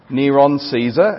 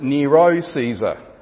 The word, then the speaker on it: Nero Caesar: The numerical values of the Hebrew spelling of the name Neron Caesar, as Greek speakers pronounced it, is 666. Now, Latin speakers pronounced it Nero Caesar with no N. They dropped the N, which made the numerical value 616.